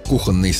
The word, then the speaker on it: кухонный: Но в живой речи вариант «кухонный» встречается довольно часто. Он привычен на слух и поэтому легко закрепляется, хотя нормой не считается. Иногда его даже не замечают, пока не нужно произнести слово правильно. Чтобы не путаться, помогает простая логика. «Кухня», «кухонный».